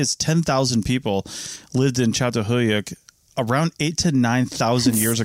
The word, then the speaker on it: Çatalhöyük: And again, we're talking about Çatalhöyük in Turkey, and that is how you pronounce it.